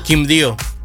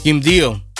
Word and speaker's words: Quindío: Quindío. – ¿Cómo? – Quindío. – ¿En inglés? Quindío.